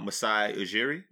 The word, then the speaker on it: Masai Ujiri: But let me tell you somebody else who's got a verified account, Masai Ujiri.